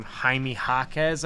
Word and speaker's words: Jaime Jaquez: They're going to be asking a lot out of, now I know how to say his name, Jaime Jaquez. I think I learned that on the pod. Jaquez.